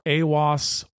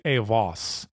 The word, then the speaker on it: Eiwaz: it's spelled E-I-W-A-Z. And I've heard many different pronunciations, but the two that I go between are AWAS or AVAS. So I tend to say AVAS.